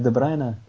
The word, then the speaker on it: De Bruyne: Bruyne, É De Bruyne? Ok. – quando U e I, seja I normal ou Y, tu lês A.